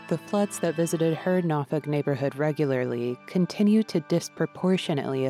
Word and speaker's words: Norfolk: Matter of fact, residents had to teach me to pronounce it. It's spelled N-O-R-F-O-L-K, but I've been instructed to pronounce it Norfolk, though really people from different parts of the city say it different ways.